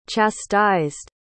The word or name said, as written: chastised